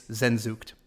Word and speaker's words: Sehnsucht: So it's Zenzucht.